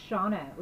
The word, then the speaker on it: Shauna: But aunt is the correct way to say it, because if somebody calls me Shanna, I'm gonna lose my shoes.